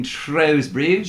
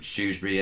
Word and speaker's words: Shrewsbury: Because of the cafe in Shrewsbury. – Shrewsbury, yeah, sorry. I was born there, so I should know how to say it properly.